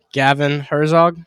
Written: Herzog

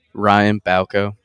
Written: Ryan Bauco

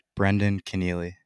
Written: Brendan Kenneally

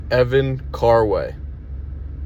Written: Evan Carway

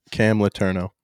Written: Cam Letourneau

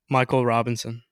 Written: Michael Robinson

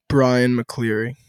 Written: Bryan McCleary

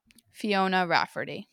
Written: Rafferty

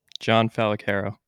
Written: Jonathan Fallacaro

